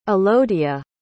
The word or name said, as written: alodia